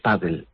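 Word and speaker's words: pádel: Nosotros podemos coger esa palabra que es P-A-D-D-L-E y decir, ¿cómo pronuncio yo eso? Paddle. Pues lo escribo paddle, con la tilde en la A incluida, porque es una palabra grave terminada en L.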